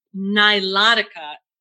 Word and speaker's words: Nilotica: Nilotica, is that how you pronounce it? – Yes, exactly.